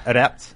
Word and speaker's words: Adept: How do you pronounce it? 8-up? – Adept.